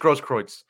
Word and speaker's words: Grosskreutz: It's very weird pronunciation. Yeah, Gage Grosskreutz. That's how they were pronouncing it when I was watching the trial.